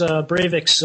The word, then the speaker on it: Breivik: but what do you think is Breivik's position on the Jews, and why do you think he's failed to progress as far as maybe he should have? – I'm pretty sure it's pronounced Breivik.